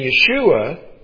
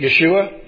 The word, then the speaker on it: Yeshua: And I got a little confused on this, and this is why I wanted to get into it, because Joshua and Yeshua are not the same in Hebrew. Joshua is Yehoshua, where salvation is Yeshua. The long O sound is not in there where it is in Joshua, all right?